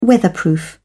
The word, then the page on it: weatherproof: British English